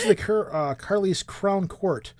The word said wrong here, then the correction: Carlisle: Carlisle Crown Court.